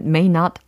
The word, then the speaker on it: Nott: 영어식으로 발음하면 may not 아닌가요? – yeah, May like the month of May. – 어. And then not. N-O-T-T, double T.